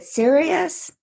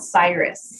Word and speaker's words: SYRUS: So then the project that I thought was really cool that we talked about is it is it Sirius? Did I say that correctly? It's called SYRUS.